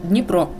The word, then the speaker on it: Дніпро: Частина науковців переконана, ця назва має однаково вимовлятись і українською, і російською, і англійською – Дніпро.